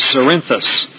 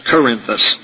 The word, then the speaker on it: Cerinthus: Gnosticism was brought into Christianity by Simon Magus, and also by another individual I'll be discussing, a man named Cerinthus, C-E-R-I-N-T-H-U-S. So it predates Christianity and was brought into Christianity by Simon Magus and Cerinthus, sometimes pronounced Corinthus.